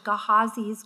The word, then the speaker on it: Gehazi: My Bible calls this next passage Gehazi's greed. Gehazi, that's it. I got it.